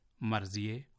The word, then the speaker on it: مرضیہ: آپ نے توجہ کی ہوگی کہ ان کا تلفظ فارسی میں رضیے, مرضیے اور طاہرے کیا گیا ہے, یعنی چھوٹی ہے کو جو قاعدہ ہم نے آپ کو بتایا ہے اس کے تحت بڑیے میں تبدیل کر دیا گیا ہے.